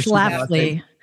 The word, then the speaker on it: Schlafly: Schlafly.